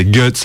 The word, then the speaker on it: Guts: Ouais, donc c'est Guts en fait, la vraie prononciation, mais on s'amuse à le dire en allemand parce qu'on sait parler un peu allemand aussi.